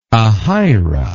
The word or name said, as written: Ahira